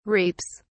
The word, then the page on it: reaps: British English